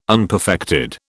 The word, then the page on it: unperfected: British English